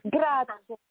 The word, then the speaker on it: grazie: Grazie. Grazie. – What does she say? – Grazie. – Oh, grazie. – Grazie. – Grazie. Or ciao. Oh, that's easy. – Yes. – Ciao. – Ciao, grazie, mille grazie.